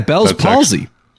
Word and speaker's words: Bell's palsy: Yeah, Bell's Palsy.